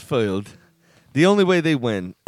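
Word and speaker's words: Fylde: Went on, did the Google pronunciate, and they said, filed. – All right. – So in the little British accent. Went, filed.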